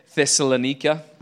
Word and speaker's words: Thessalonica: Did I pronounce it correctly, Frederick? You pretty, probably know. Pretty close. – Yeah. Thessalonica.